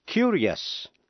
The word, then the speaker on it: curious: Sau đây là phát âm làm hai âm tiết, những chữ không có âm C, T hay X đứng trước, I-O-U-S, và phát âm là ears. Curious, C-U-R-I-O-U-S, nghĩa là tò mò.